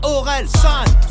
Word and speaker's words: Orelsan: se prononce Aurel, San, San, San, San, San, San, San, San, San, San, San, San, San, San, San, San.